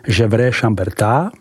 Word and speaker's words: Gevrey Chambertin: Vyslovujeme Gevrey Chamberta a to celkem 8.